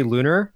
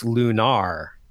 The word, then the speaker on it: Lunar: But in this case, yeah, it's one of those things where it's just supposed to be Lunar. But for whatever reason, Working Designs, when they localized it, said, no, it's Lunar, and emphasized that second syllable.